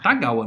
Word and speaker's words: Tagawa: Takagawa. Takagawa. Tagawa, não tem esse K no meio, não.